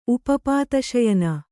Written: ಉಪಪಾತಶಯನ

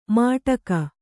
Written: ಮಾಟಕ

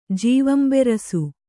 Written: ಜೀವಂಬೆರಸು

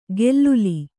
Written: ಗೆಲ್ಲುಲಿ

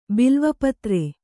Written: ಬಿಲ್ವಪತ್ರೆ

